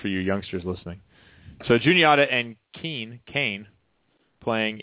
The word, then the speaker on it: Juniata: Juniata. – Juniata? Really? There's no E. How do you get a U sound with no E? – You use the Native American pronunciation for the river that it sits on.